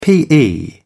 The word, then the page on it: pe: British English